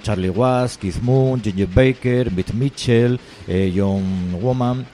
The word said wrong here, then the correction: Bonham: Bueno, dicen John Bonham, dicen. – Sí, bueno, yo Bonham. – Dije, no, es que lo pronuncio yo mal.